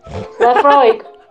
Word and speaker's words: Laphroaig: Laphroaig. – Laphroaig. – Yeah, I did that as well. – It's Laphroaig.